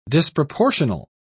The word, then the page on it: disproportional: British English